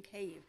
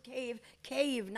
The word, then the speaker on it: Kyiv: And please, if you heard Zelensky, he pronounces Kyiv, not Kyiv. – Yeah. – They right. all say Kyiv. Even the news journalists say Kyiv. It's not. Stop it. That's the Russians.